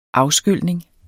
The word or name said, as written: afskylning